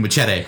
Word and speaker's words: Machete: Yeah, De Niro was, saying Machete because I liked the correct pronunciation of it.